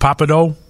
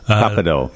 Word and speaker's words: Pappadeaux: Yeah, but I have to say it's pronounced Papadou. – Papadou. – Yeah, they corrected me several times.